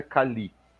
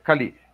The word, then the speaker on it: Kali: Chama-se, a pronúncia correta é Kali. Uhum. Em filipino, Kali quer dizer espada, lâmina. Tá? Uhum. É Kali.